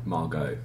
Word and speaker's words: Margot: Margot.